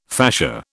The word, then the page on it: fascia: British English